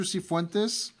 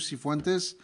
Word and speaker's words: Cifuentes: It's Instagram at Mr. Cifuentes. It's Instagram / Mr. Cifuentes. – Spell that. – You're not going to know how to spell it. – M-I-S-T-E-R-S-I-F as in Frank, U-E-N-T-E-S.